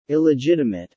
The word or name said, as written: illegitimate